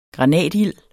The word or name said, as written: granatild